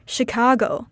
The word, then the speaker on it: Chicago: The state of Chicago in the United States is also pronounced like Chicago with a sh sound rather than a ch sound.